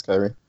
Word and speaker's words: Khyri: D Rose, Tony Snell, Kyrie Thomas, spelled Khyri. Right? That's Yeah, that's how you say it. – Kyrie? Kyrie? – It's Kyrie.